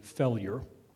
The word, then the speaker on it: Fellure: Mr. Failure, it's spelled F-E-L-L-U-R-E, Failure. He's been running since 1988,